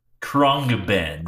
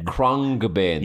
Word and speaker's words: Khruangbin: Khruangbin. – Khruangbin. Khruangbin.